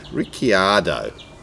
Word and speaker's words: Ricciardo: Daniel Ricciardo, Riccardello, sorry, uh, how do you say his name? Ricciardo.